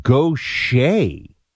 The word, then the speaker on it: Gautier: How to pronounce Gautier, it's Gautier. I'm from Mississippi, Paul says. I would not have gotten this.